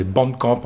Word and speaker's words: Bandcamp: Alors pour les gens qui ne seraient pas anglophones, Bandcamp, ça s'écrit avec un A, hein, c'est Bandcamp en fait. – Bandcamp, B-A-N-D-C-A-M-P.